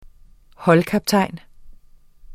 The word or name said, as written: holdkaptajn